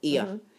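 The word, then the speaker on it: Oia: We also got the airport bus to Oya, a place called Oya. Ia. It's spelt with an O, O-I-A.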